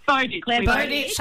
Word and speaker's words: Bowditch: Bowditch.